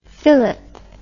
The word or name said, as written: filip